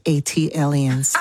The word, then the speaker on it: Atlian: That is pronounced Atlians. – Ah, thank you. – Like Atlanta alien, Atlian.